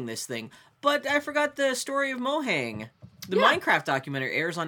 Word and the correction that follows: Mojang: Mohang or Mojang? – At PAX, that's how people were pronouncing it. – I never know. – Mojang.